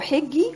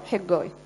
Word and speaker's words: حجي: هو سفر حجاي عبارة عن اصحاحين، هو في ناس بتنطقه حجي وفي ناس بتنطقه حجاي، ماشي.